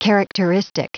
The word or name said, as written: characteristic